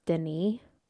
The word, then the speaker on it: Denis: the director, which I believe on a previous episode I said his name was Dennis and I think it's pronounced Denis. You don't pronounce the S, Katie.